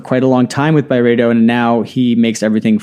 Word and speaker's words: Byredo: Ben Gorham, the founder of Byredo, or Byredo, how did he pronounce it again? – Byredo. That's the scoop. – Yeah. We've been pronouncing Byredo wrong. Byredo. It's Byredo, right? – I mean, that's what I heard.